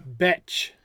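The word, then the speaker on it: Bethe: Betch. – She's a what? – B-E-T-H, Betch. – No, it's B-E-T-H-E. – Oh, that's right. There's a silent E.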